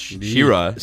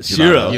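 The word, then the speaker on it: Ksira: Actually pronounced Shira. – Shira. – You learned it. – Yes. – Yo, you can't forget the K though. People got to search up Shira and be like, what the hell? – Yeah, the K is silent. It's K-S-I-R-A.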